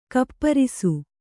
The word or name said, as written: ಗೋಪರಸ